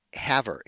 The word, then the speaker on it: Havre: And in this case, the city we were picking was a city called Haver, and it's spelled H-A-V-R-E. Haver.